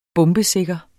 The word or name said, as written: bombesikker